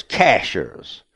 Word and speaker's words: Cashiers: Our name our town is Cashiers. – Okay. – He says we don't pronounce it like a bank cashier. But anyhow, Cashiers.